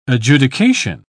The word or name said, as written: adjudication